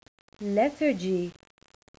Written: lethargy

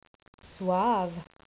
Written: suave

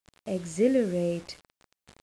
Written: exhilarate

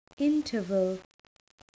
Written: interval

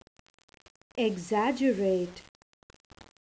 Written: exaggerate

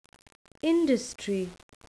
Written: industry

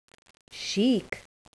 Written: chic

